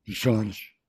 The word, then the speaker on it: Jorge: Is that Portuguese pronunciation is Jorge.